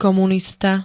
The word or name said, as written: komunista